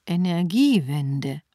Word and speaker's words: Energiewende: Sa Energiewende? Puh, die englische Aussprache ist wirklich schwierig. – Ah, gut, du machst deine Englischhausaufgaben. Aber das ist eigentlich ein deutsches Wort. Energiewende.